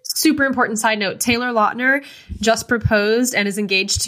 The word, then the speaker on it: Lautner: I mean, literally, I think it was Summit just posted a video of fucking Taylor Lautner, Lautner I found out we've been saying his last name wrong this entire time. Taylor Lautner nice. saying, "Where the hell have you been, Loca?" again, and it's he just said it like, on Jimmy Kimmel.